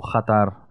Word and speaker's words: Qatar: Qatar, or Qatar, as its real name is to be pronounced.